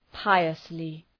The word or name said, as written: piously